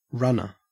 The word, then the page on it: runner: Australian English